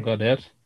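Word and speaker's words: Gaudette: Yeah, Gaudet.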